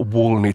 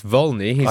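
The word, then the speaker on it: Wollny: Next artist, or actually next group, is called Michael Wolny Trio. What can you tell us about this? – Well, Michael, I'll have to correct your pronunciation. It's Wolny.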